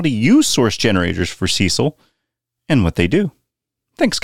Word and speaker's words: Cesil: Well, if you do, Cecil has you covered. That's spelled C-E-S-I-L.